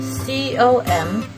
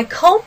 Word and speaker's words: comb: That is, 啊、uh,，我要梳一梳我的头发。梳这个词呢，叫做 C O M B。猜一下这个词怎么念呢？啊，是不是念 comb 啊，老师？OK，I、okay, comb my hair. Is that right? Sorry, baby, it's not right. 这个不是念 com，OK，、okay? 是念什么呢？comb，comb，OK。I comb my hair.